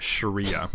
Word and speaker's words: Sharia: I'm sorry, so I will henceforth amend my pronunciation and Sharia. Call it Sharia.